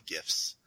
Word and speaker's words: GIF: I refuse to call it GIF because GIF is a peanut butter and it's not giraffics.